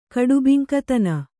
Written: ಕಡುಬಿಂಕತನ

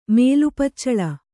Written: ಮೇಲುಪಚ್ಚಳ

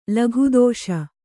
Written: ಲಘುದೋಷ

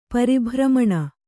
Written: ಪರಿಭ್ರಮಣ

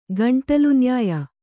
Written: ಗಂಟಲುನ್ಯಾಯ

